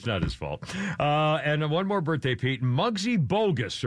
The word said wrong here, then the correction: Bogues: or Bogue. Is it Bogues? Muggsy Bogues. – As I pronounce, Muggsy Bogues, 59 years old, former NBA dunker who is 5'3".